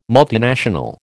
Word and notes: multinational: American English

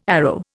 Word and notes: arrow: Indian

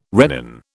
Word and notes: rennin: British English

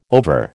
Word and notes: over: American English

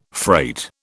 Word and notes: freight: British English

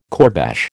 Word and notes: kourbash: American English

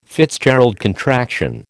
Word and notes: FitzGerald contraction: American English

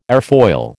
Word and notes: airfoil: American English

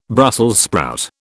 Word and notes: Brussels sprout: British English

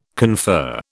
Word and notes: confer: British English

